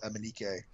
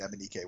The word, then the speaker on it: Emenike: I also think we could be seeing the debut of Emmanuel Emenike. – Yeah. – Sorry, Emenik. – Emenik, I keep pronouncing it Emenike, but it's not true.